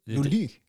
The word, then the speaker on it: Lully: Jean og så er det Lully. Lully? – Lully. Hvordan Jeg ved det, ikke noget. Uh, øh, L-U-L-L-Y. – Lully.